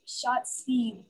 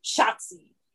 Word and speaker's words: Shotzi: Yelling Yahtzee, but Shotzi. – Yeah. – Shotzi. That's how we're just gonna say her name from now on. We're just gonna scream it like we would scream Yahtzee.